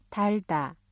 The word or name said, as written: 달다